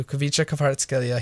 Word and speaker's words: Kvicha Kvaratskhelia: Kvicha Kvaratskhelia. He's so good. – Kvicha Kvaratskhelia.